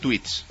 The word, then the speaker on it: tweets: y voy a dar mis dos últimos tweets. Se dice así, ¿no? ¿Es correcto? Tweets. – Sí, está bien.